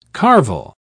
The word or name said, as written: carvel